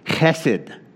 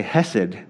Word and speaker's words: chesed: The word is chesed. And if you say chesed, you're saying it wrong. You got to have a little phlegm in your throat. Chesed.